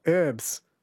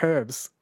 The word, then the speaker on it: herbs: I get irritated when I hear Americans say herbs for herbs when there is clearly an H.